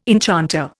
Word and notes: enchanter: Indian

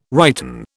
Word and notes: righten: British English

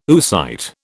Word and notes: oocyte: British English

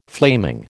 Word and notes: flaming: American English

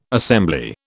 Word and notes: assembly: British English

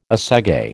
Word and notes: assegai: American English

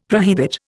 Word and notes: prohibit: Indian

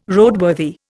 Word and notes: Roadworthy: Indian

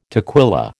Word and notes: toquilla: American English

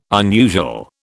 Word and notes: unusual: British English

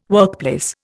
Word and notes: workplace: Indian

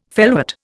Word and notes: felwort: Indian

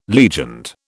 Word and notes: legioned: British English

